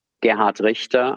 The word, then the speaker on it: Gerhard Richter: So, Gerhard Richter, I'm pronouncing this deliberately in German and not in my German American accent.